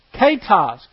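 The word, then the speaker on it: ketos (κῆτος): And the Greek word there is ketos, K-E-T-O-S. It's an eta instead of an epsilon, even though it's an E, it has an A pronunciation.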